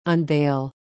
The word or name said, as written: unveil